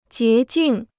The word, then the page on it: 洁净: jié jìng